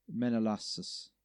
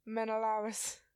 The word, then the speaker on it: Menelaus: Let's see when we can butcher this one. Menelaus. – Nope. – How do you say it then? – Menelaus.